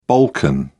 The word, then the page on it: balkan: British English